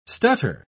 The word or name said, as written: stutter